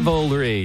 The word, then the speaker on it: Cavalry: Coming up at 7:40, Flatland Cavalry. Am I saying it right this time? – Cavalry, yeah.